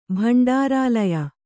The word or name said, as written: ಭಂಡಾರಾಲಯ